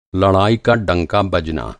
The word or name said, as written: डंका